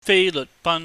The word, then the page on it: feileuhtban: cantonese